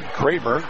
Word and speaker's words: Kremer: Pronounced like mine, Kramer, but spelled K-R-E-M-E-R, not K-R-A.